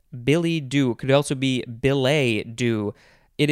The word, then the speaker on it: billet-doux: And then the second word is doux, D-O-U-X. Do either of you speak French? – I took French in high school. – Did I pronounce that correctly, do you think? – I would pronounce it billet doux. – Billet doux. Yes, I think that is more correct.